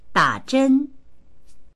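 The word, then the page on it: da3zhen1: Mandarin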